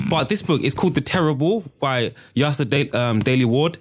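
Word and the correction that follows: Yrsa: Oh, Irsa. – Is it? How do you pronounce it? – I think it's Irsa.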